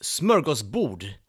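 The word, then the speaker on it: smörgåsbord: Now, the idea of smorgasbord actually originates in Sweden, pronounced smorgasbord in Swedish.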